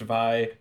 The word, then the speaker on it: Vi: It is pronounced Vi because it is short for violence, is something that I learned at some point.